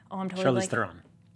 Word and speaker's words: Charlize Theron: There are Japanese actors if you go down the list of voice actors, but the main ones, like Charlize Theron, Matthew McConaughey, and, Charlize Theron.